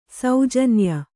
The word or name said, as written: ಸೌಜನ್ಯ